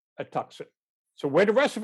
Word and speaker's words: Tucson: And the woman says, well, we can put you in a Tuscan. And the whole thing kind of unrolls with him going, you mean Tucson? She goes, is that how you say it? He goes, yeah.